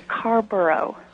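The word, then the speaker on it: Carrboro: We have calling from Carbo, North Carolina. Did I pronounce that correctly? – You've got right. It's actually Carborough.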